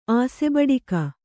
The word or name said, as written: ಆಸೆಬಡಿಕ